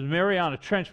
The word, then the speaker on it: Mariana Trench: I was correct at mispronunciation, but I Googled it, and whatever worth Google is, right, is Mariana Trench.